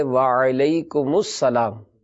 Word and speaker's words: وعلیکم السلام: سلام کا جو جواب ہے اس کا درست تلفظ ہے وعلیکم السلام۔